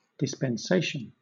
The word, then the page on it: dispensation: British English